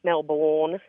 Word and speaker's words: Melbourne: I know you're from Melbourne, and I know that you're not supposed to pronounce the R, right? Melbourne? – Melbourne. Yeah, Melbourne.